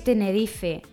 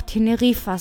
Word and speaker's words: Tenerife: Esa es Tenerife. La ciudad se escribe con una sola F y acaba en E. Pertenece a las Islas Canarias. Die Deutschen würden jedoch Teneriffa sagen.